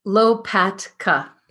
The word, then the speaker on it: Lopatka: well, Lopatka. It's pretty phonetic. – Lopatka. That TK okay. kind of trips people up.